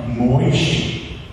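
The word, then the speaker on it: Moishe (משה): names him in the Hebrew tongue, Moishe, which we pronounce in English, Moses.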